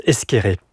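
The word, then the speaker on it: Esquerre: Oui, parce que c'est un nom qui vient du sud-ouest de la France, où ça se prononce Esquerret.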